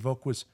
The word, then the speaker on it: Woog: VOHK was a term that close friends used to call me that, because that was the proper German pronunciation of my last name, which is spelled W-O-O-G. – Right. – And the phonetic spelling was V-O-H-K.